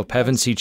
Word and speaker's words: Pevensey: who didn't acknowledge that the plight of these four Pervensi, I can't pronounce her last name. Per, there we go, Pevensey children.